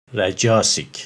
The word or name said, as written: Rajasic